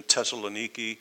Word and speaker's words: Thessaloniki: I'll say Thessalonia, Thessalonica, and sometimes I'll slip into the modern pronunciation, Thessaloniki.